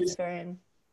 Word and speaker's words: Luciferian: And a few of them are like Luciferian. Is that how you say it? Luciferian witches. – Luciferian.